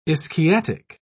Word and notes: ischiatic: British English

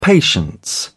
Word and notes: patience: British English

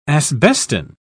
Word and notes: asbestine: British English

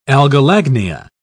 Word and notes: algolagnia: British English

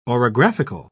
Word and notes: orographical: British English

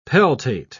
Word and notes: peltate: British English

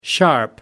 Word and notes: sharp: American English

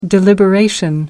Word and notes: deliberation: American English

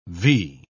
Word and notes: vee: British English

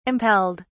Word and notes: impelled: British English

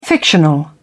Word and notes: fictional: British English

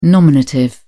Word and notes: nominative: British English

